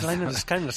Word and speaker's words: Lynyrd Skynyrd: Yo creo que es Lynyrd Skynyrd, se debe pronunciar.